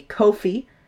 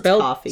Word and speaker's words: Ko-fi: And on our website, you can also find some shirts if you would like to buy them, and coffee mugs. It's another way to help support the show. We do have a Ko-fi. Ko-fi? Ko-fi? K-O-F-I. However, it's coffee. – Spelled coffee, pronounced coffee. Spelled Ko-fi, pronounced coffee.